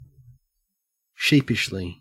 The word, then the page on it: sheepishly: Australian English